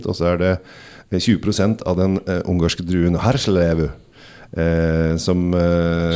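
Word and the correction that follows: Hárslevelű: som du nå uttalte på dansk av en eller annen grunn? Men Herslevlevu.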